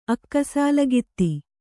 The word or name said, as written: ಅಕ್ಕಸಾಲಗಿತ್ತಿ